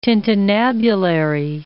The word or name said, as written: tintinnabulary